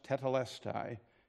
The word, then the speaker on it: tetelestai: It's pronounced tetelestai.